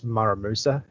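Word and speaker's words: Muramasa: There's also an indie game called Dust, which sort of borrows heavily from, I think it's Muramusa is how you say it.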